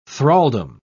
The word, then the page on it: thraldom: British English